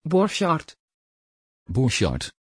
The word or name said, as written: borchard